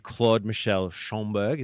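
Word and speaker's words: Claude-Michel Schönberg: And Claude Michel Schomburg, is that how I pronounce it? That's how you say it.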